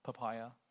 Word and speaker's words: Papaya: And as we look at, as I wrote up their prayer needs, and the, if I'm pronouncing this right, Papaya Gang, papaya. It's what? Like the fruit, papaya. That's how it's pronounced.